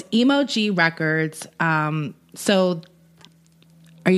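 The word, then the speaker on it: EmoG: Emoji, though, it's spelled E-M-O capital G. – Oh. – Emoji.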